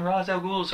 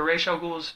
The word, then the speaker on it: Ra's al Ghul: Instead of having her, at the end, reveal, oh yeah, I'm Ra's al Ghul's, or Ra's al Ghul's, depending on which pronunciation you go with.